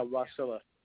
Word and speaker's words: Wasilla: So, and how do you pronounce that? Wasilla, Alaska? – Yeah, Wasilla.